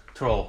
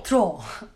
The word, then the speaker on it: Thoreau: Onun okunuşu Henry biraz... Henry David Thoreau. – Thoreau. – Thoreau.